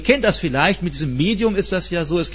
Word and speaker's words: Medium: Und dann gibt es Leute beim Steak, die wollen das gerne Medium, das eine muss englisch ausgesprochen, meint aber dasselbe, nämlich Medium.